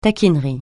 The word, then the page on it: taquinerie: francais